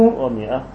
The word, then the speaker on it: مئة: اسمه ومئة، لا الألف، الألف لا تلفظ.